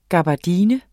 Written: gabardine

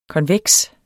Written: KONVEKST

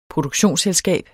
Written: produktionsselskab

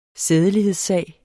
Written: sædelighedssag

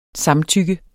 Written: samtykke

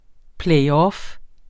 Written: playoff

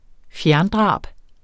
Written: fjerndrab

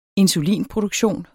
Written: insulinproduktion

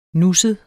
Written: nusset

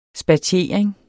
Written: spatiering

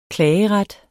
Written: klageret

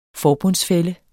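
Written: FORBUNDSFÆLLES

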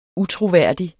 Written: utroværdig

